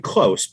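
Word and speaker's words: Kloos: He pronounces it Justin Close, but of course, this being hockey, his nickname is the Closer.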